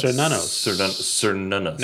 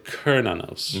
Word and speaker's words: Cernunnos: Uh, it's Serenus. Cernunnos. Now, some people would say Kernanos, mm-hmm. but uh, I have the official pronunciation on Google as Cernunnos, the uh, antler horned god.